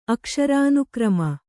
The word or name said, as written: ಅಕ್ಷರಾನುಕ್ರಮ